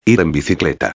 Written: ir en bicicleta

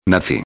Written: naci

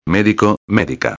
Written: medico-a

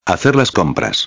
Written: hacer las compras

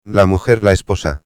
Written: la mujer/la esposa

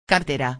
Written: cartera

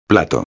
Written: plato

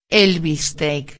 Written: el bistec

